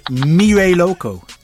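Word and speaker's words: Mira Loco: And it's called Mire Loco, featuring Sea Traffic. Did I pronounce that correct? – That's right.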